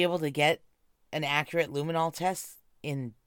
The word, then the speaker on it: luminol: Yeah, there were Illuminati tests done in the woods. Oh, is that how you say it? Luminol? Okay. There were luminol tests done in the woods, too.